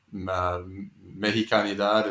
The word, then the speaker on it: Mexicanidad: So I wonder if you can talk a little bit about, José Nápoles and Mexicanidad. Is that how you pronounce that yes. – word? – Yes, that's a very good pronunciation.